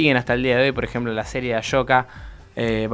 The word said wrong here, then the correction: Ahsoka: va, Ahsoka, digo porque lo pronuncio yo así, Ashoka, pero es Ahsoka.